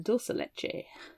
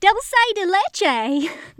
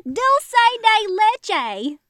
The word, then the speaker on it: Dulce de Leche: Now, see, I was really hoping that I would have gotten this paragraph to read because it really bothered me how honky-tonk they pronounced these words. She's got Rocky Road and Dulce de Leche. Dulce de Leche.